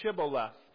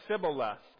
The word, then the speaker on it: Shibboleth: When he said, "No," they said to him, "Then say Shibboleth." And he said, "Sibboleth," for he could not pronounce it.